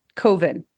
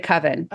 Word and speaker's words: coven: And I pronounced it Coven. Instead of Coven.